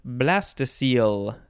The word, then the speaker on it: blastocoel: Next is blastocoel, B-L-A-S-T-O-C-O-E-L, or you could add another E at the very end, blastocoel. It is not pronounced how you would think it would be based on how it is spelled.